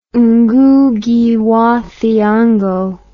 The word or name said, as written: Ngugi wa Thiongo